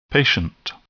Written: patient

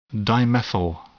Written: dimethyl

